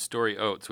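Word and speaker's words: Stori Oates: So it's Story Oates, which is just a fantastic name.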